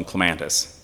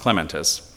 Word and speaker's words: clematis: Now, I grew up with, we call them clematis in Western Pennsylvania, but I think it's properly pronounced clematis.